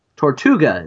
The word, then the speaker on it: Tortuga: Tortuga. That's the correct pronunciation? – Yep.